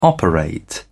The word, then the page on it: operate: British English